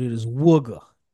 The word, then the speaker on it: Wooga: I know the people from Philly will come in and correct me if we don't say his name right, but it is Wooga. – Wooga. – Wooga. It's part of the Philly accent.